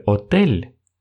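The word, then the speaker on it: hotel: Например, в слове «отель» эта буква находится первой но, как вы услышали, я ее не произношу.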